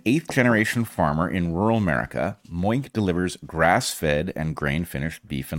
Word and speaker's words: Moink: That's Moo plus Oink. – And how do you pronounce that? Exactly. – All right. Moink is a meat subscription company on a mission to save the family farm.